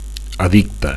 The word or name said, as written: addicte